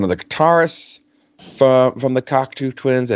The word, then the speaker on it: Cocteau Twins: Was it the Cocteau Twins? Is that what it was pronounced, Joe? – Yep, Cocteau Twins.